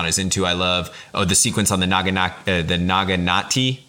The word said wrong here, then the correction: naginata: Oh, I always say that wrong. The long spear with the knife, the one that's the traditional weapon of like the women. Naginata do. Yeah.